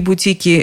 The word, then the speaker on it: бутик: Как можно понять, в единственном и во множественном числе ударение всегда на «и». Он зашел в бутик, вышел из бутика, он гордится своим бутиком. Если же бутиков много, то хозяин гордится своими бутиками.